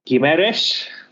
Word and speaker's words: Guimarães: Guimarães.